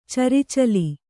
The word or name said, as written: ಚರಿಚಲಿ